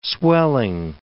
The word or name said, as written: swelling